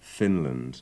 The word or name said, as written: Finland